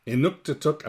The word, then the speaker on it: Inuktitut: So is Central Siberian Yupik and Inuktitut, am I saying that right? – That was right. You nailed it.